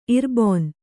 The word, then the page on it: ಇರ್ಬೊನ್: irbon